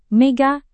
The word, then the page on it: mega: Indonesian